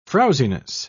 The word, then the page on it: frowziness: British English